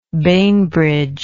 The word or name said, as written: bainbridge